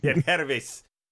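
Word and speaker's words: Yangervis: In that doubleheader, of course, we talked about everybody except Yenger versus Solarte. – Yen Hervis. – Okay, why is the G pronounced like an H?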